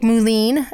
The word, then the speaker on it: mullein: It looks like it's spelled M-U-L-L-E-I-N, and it looks like mullein. It's pronounced mullein.